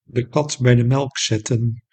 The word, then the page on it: de kat bij de melk zetten: Dutch; / dəˈkɑt ˌbɛidəˈmɛl ə kˌsɛtə(n) /; 7 of 8 lettergrepen